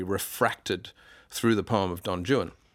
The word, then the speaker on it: Don Juan: His poem Don Juan, and he makes you pronounce it Don Juan as well, not Juan, because he rhymes it with new one and true one.